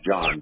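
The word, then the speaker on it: Jon: No, my name is John, but that's all right.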